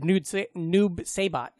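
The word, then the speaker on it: Noob Saibot: Noob Sabat? – We called it Noob Sabat is how we pronounce it.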